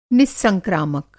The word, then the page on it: निस्संक्रामक: Hindi